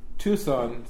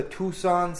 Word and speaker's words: Tucson: Tucson is the only word that I know that has a silent C in the middle of it.